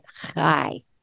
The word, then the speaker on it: chai: Now, I said the word high, but you actually say it high. And it's spelled like chai tea. But it has nothing to do with chai tea. It's spelled C-H-A-I.